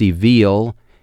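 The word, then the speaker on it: evil (אֱוִיל): Now, that word for fools there is evil. Almost looks like evil when you look at it written out, but it's pronounced evil.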